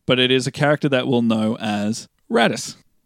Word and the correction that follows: Raditz: Am I pronouncing that right? – Raditz. – Raditz.